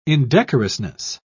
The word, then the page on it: indecorousness: British English